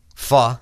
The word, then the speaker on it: pho: P-H-O. People say pho. Pho is... – Yep, the pronunciation is pho.